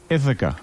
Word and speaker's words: Ithaca: Ithaca. I-T-H-A-C-A.